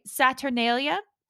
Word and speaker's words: Saturnalia: We have Daisy with embrace the debauchery and traditions of, I hope I'm saying this right, Saturnalia. – Yeah. As far as I know. Yeah. It's how you say it.